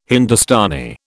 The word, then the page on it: Hindustani: British English